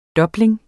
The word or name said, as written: DOBLING